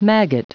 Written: maggot

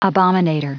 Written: abominator

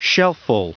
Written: shelfful

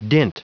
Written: dint

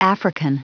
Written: african

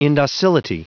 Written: indocility

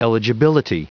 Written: eligibility

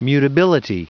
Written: mutability

